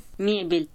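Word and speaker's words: Mebel (мебель): What about Russian? – Mebel. – That's the Russian pronunciation.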